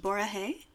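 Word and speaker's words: Borahae (보라해): When you say it in Korean, it's pronounced Borahae, which is a combination of Bora, or purple, and Saranghae, which is I love you.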